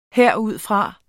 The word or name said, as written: herudfra